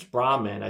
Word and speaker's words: Brahman: And they said, your name's Brahman.